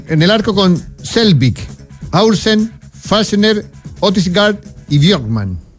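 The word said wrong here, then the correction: Bjørkan: Bjorkam, perdón.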